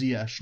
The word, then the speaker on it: Ziyech: Confirmed that it's pronounced Ziyech now, right, Andreas? So we were right. – Correct. – The announcers were wrong.